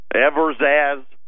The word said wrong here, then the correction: Evraz: E-V-R-A-Z, that wasn't even close. Evraz, that is their metals, their big metals company.